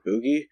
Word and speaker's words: Boojie: Yeah, Boogie Boy is B-O-O-J-I-E, but I feel like you pronounce it Boogie.